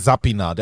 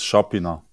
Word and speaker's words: Šapina: Wie, der eine sagt Sapina, der andere sagt Schapina, jetzt Schapina. Wie genau spricht man deinen Nachnamen aus? – Das Richtige, also richtig ist es Schapina.